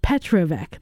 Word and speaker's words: Petrovic: Petrovic.